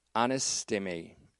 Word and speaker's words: anistemi: And that is anistemi, A N I S T long E M I.